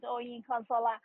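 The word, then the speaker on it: Oyinkansola: You could never butcher my name. It's just the way it's spelled. It's Oyin Consola.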